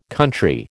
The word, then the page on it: country: American English